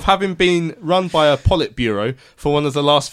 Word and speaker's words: Politburo: Politburo.